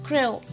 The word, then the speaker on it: krill: スペルを言いますと KRILL で読み方としてはクレオ